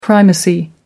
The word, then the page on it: primacy: American English